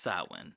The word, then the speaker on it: Samhain: I don't know, but it's a Gaelic word. It's pronounced Samhain.